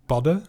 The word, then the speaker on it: padden: Padden, dat is eigenlijk gewoon padden. Die d dubbel D spreek je uit als een soort Engelse de. Padden. Maar als je het dan een Deen hoort zeggen, dan is het toch meer padden.